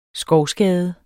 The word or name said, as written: SKOVSKADE